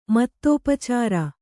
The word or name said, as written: ಮೋದೋಪಚಾರ